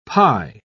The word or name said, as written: pi